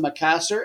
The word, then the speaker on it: Makassar: The one we're going to look at, number 3, PSM, where the M stands for, I'm going to pronounce it Makassar, M-A-K-A-S-S-A-R, ticking on number 4, Persita. Recent series between them is 1-1-1.